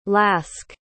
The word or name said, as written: lask